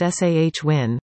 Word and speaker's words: Samhain: Originally called the pagan religious festival of Samhain, pronounced Sahwin, originated from ancient Celtic spiritual tradition.